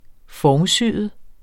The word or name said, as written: formsyet